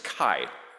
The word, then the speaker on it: chi: So here we have, it's not the letter X, but the Greek letter chi, which in English is pronounced chi for some reason.